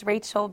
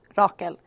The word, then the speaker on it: Rachel: So in English, it's Rachel Brayton. I've been Rachel almost my entire life, but in Swedish, it's actually pronounced Rakel.